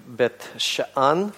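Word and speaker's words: Бет-Шаан (בית שאן): Бефсан, или более приближенно к еврейскому произношению Бет-Шаан, это город, который имеет довольно длинную историю.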